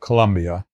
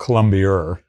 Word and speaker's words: Columbia: So having grown up in Westport, the skipper of the boat you're talking about, Columbia, or if you're from Newport, you call it Columbia-er because they can't pronounce Columbia.